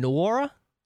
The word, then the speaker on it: Nwora: but it's Jordan Nwora. – Yeah, is that correct? – You got it. – Okay. – You got to kind of throw that W in there.